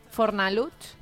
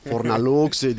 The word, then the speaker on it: Fornalutx: Tot anava a dir, Fornalutx no deu ser fàcil de pronunciar ni pels castellano parlants de la península, no? Fornalutx. – Deu dir Fornalutx. – Clar, perquè entre aquesta X final... – Sí, és veritat. Fornalux, diuen...